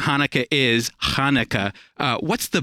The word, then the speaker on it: Hanukkah: The way I grew up is the Ch. – Yeah. – And a lot of Gentiles have difficulties with Ch. So even though it could be spelled with a Ch, you'll have people say Hanukkah all the time. I say Hanukkah. – Okay, so there's a little guttural there.